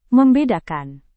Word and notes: membedakan: Indonesian

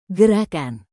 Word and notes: gerakan: Indonesian

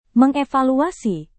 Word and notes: mengevaluasi: Indonesian

